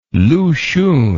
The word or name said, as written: Lu Xun